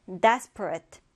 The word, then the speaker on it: desperate: 첫 음절에 강세를 두고요. 발음 주의해주세요. Desperate. desperate 이렇게 발음해 주는데, 자, 이 단어는 사람이 필사적인 또는 어떤 상황이 절박한, 긴박한 이런 뜻이 있습니다.